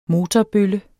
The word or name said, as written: motorbølle